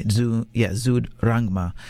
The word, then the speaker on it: Zud Rangma: So he works at this, I was talking about this record shop called Zung Drama, I'll say it wrong, in Bangkok. We're in Bangkok, by the way. Zud Rangma, which means like high horsepower, super intense.